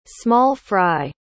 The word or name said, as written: small-fry